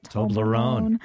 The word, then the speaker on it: Toblerone: Toblerone.